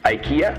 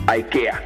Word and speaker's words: IKEA: Que por cierto hay dos pronunciaciones. Una es la versión norteamericana que es IKEA y la otra es la versión sueca que suena IKEA.